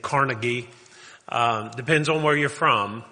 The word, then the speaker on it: Carnegie: But if you're from Western Pennsylvania, it is Carnegie. The university that bears his name and the city that was named after him is Carnegie. He came from Scotland, and the pronunciation of his name in Scotland is Carnegie.